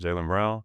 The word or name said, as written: Jaylen Brown